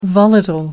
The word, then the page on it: volatile: American English